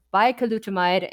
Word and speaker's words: Bicalutamide: So we have here a lovely article out of the Journal of Cosmetic Dermatology entitled Bicalutamide. There's an extra syllable in there. Bicalutamide and the new perspective for female pattern hair loss treatment, what dermatologists should know.